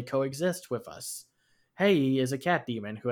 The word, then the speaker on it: Hei: So we're going to move next to China, which has another bountiful animation scene with a film called The Legend of Hei. I hope I said that correctly because it's H-E-I.